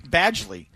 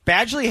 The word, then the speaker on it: Badgley: Badgley. That's how you pronounce it. Badgley had zero touchbacks last week in Baltimore on six kickoffs.